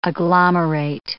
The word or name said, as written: agglomerate